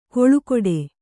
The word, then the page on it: ಕೊಳುಕೊಡೆ: koḷu koḍe